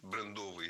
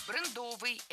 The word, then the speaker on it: брендовый: Брендовый – это неверно. В этом слове ставим ударение на первый слог «брендовый». Бренд – брендовый. Сохраняем ударение исходного существительного.